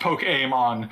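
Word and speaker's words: Pokémon: There's like, it's technically pronounced Poke-Aim-On with a short E.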